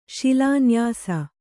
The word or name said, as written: ಶಿಲಾನ್ಯಾಸ